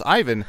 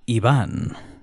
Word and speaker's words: Ivan: So the typical English spelling would be Ivan. You are correct in assuming that. But my dad's Argentinian. My parents met in Spain. So I go by Iván, the Spanish pronunciation.